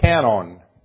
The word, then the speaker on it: kanon: The Greek word is K-A-N-O-N, the long O. K-A-N-O-N. So it's canon.